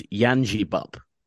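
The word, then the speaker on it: Yangebup: And he gave me a clarification. It's Yanjibup. – Yeah. – I think I was saying it with a G.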